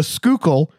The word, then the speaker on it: Schuylkill: And there is a river in Philly that I've been assured is pronounced the Schuylkill. Although, looking at the way it's spelled, that omits about half the letters.